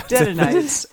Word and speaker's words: Dedanites: In the forest in Arabia shall you lodge, you caravans of the Dedantes? Dedanites? How do you say that? – Dedanites? – It's like, thank you. It's like my people. – Yeah. Dedanites. Oh, Dedanites.